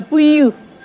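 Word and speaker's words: püllü: ¿Cómo la escribimos? P-U con cremilla, el doble L-U con cremilla, puyú, es el suelo, en la tierra misma, el suelo, puyú.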